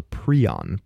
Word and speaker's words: prion: a prion, P-R-I-O-N, or a prion, if you're pronouncing it the British way.